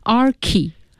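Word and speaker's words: archi: 对，好，他在做前缀的时候呢，就不读 arch 了，读成 archy，比如说 architecture。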